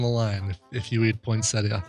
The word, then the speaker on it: poinsettia: Poinsettia. – Poinsettia. Should be an accent on the next to last syllable.